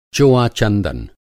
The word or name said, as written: चोआ-चंदन